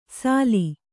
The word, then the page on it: ಸಾಲಿ: sāli